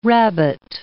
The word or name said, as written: rabbet